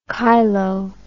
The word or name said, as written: cheilo